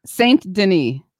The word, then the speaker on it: Saint-Denis: Oh, how do you say it? – Saint-Denis. – Saint-Denis. Yeah. I was trying to be more French.